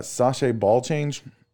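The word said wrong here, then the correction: chassé: Chasse. – Chasse. Okay, that's close.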